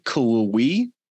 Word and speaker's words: Cullowhee: All right, so how do you pronounce the name of the town in Western Carolina? Is it Cullowhee? – Yeah.